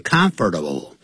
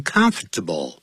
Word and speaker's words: comfortable: Comfortable 是四个音节，可是老外常常不会讲 comfortable。它实际会念成 comfortable 变三个音节，必须要很舒适才行。